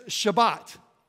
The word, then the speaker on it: Shabbat: experienced Shabbat. That's Sabbath. We say it wrong. It's called Shabbat. Say that with me. Shabbat.